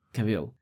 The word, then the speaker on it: Cavill: It's I-double-L. Like Hill. Cavill.